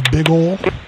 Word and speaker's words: Bigole: Yes, last name is Johnson, and first name is Big Ol. B-I-G-O-L-E. – Can you spell that? – Yep, B-I-G-O-L-E. Big Ol Johnson. – B-I-G. – O-L-E.